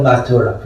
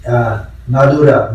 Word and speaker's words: Mathura: The magician Lord, the boy from the northern Mathura, because if you say Madura, and then Madurai, Mathura in Tamil pronunciation, Madura, and then Madurai. So it actually means Mathura.